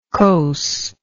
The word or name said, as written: coss